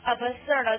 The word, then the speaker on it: absurd: 但你怎么记呀、啊、？absurd 看它发音 absurd 爱不死的 absurd 对不对？